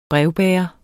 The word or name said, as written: brevbærer